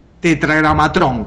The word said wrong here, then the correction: Tetragramatón: ahí va. Tetragramatrón, tetragramatrón. No, tetragramatrón es nombre de un robot asesino. – Sí. – Yo esto estoy diciendo tetragramatón.